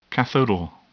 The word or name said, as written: cathodal